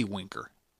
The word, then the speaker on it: Winker: What would you think of a strategy where the Reds trade players like Scooter Schebler and Winkler? Winkler, Henry Winkler, the Fonz. No, no, no, Jesse Winker. Jeff Walter uh, spelled it right. I just pronounced it wrong.